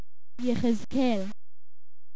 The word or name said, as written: Yekhezql